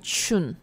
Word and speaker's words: 中: 然后到第五个，这个就更明显了哈，中就是念 chun。